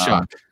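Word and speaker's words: Shough: He says um, Shuck. – So yeah, yeah, well, I mean, I, the last time I looked at a O U G H ending, you don't pronounce it with a C K in there. You know, I'm not an English major, but uh, I, I, don't know why you pronounce that Shuck,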